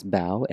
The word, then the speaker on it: Bao: So my name is Bao, and it's spelled B-A-O.